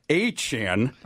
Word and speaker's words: Achane: I'm going to give both Mostert and Achane, as we've learned, is the pronunciation.